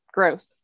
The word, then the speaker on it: Grose: Have I said that right? – Absolutely correct. It's Grose.